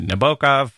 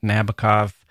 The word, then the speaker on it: Nabokov: He referred to Nabokov, or in the British pronunciation, Nabokov, in Don't Stand So Close to Me.